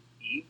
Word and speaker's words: Eave: it, it is E A V E. Eve.